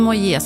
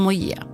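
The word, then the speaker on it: Moyet: Allison Moje ska uttalas med sista... Ja, inte uttalas med sista bokstaven, inget T alltså. Allison Moje.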